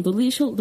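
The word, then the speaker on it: Dolezal: Det finns en kvinna som heter Rachel Delisial Eh, jag uttalar, jag uttalar som de uttalar eh, det stavas D-O-L-E-Z-A-L. E mm. Och eh, jag har hört att hon uttalar sitt namn Dolishol, då gör jag också det.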